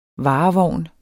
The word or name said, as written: varevogn